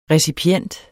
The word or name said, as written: recipient